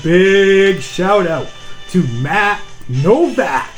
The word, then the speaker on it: Nowack: He said it's pronounced Novak. So, congratulations, Matt. – Let's make a big deal about this. Now, his last name is spelled N-O-W-A-C-K. So, isn't it Novak? – Excuse me, while people from my part of town, we pronounce it Novak.